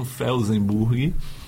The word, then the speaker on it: Felsenburg: Parece um nome difícil, mas é o alemão é legal, que escreve como se fala mesmo, Felsenburg.